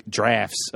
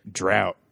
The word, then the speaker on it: draught: D-R-A-U-G-H-T? – Right. – And he was like, he made me do the line over and say it drought instead of draft. – Wow. – And I was like... That's not how it's pronounced.